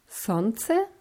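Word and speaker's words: Sonze (Солнце): Das eine Wort ist Sonze.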